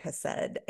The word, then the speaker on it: hesed: So in your research, you found that the Hebrew word for steadfast love is hesed. Am I pronouncing that right? – Hesed. Yeah. You got it.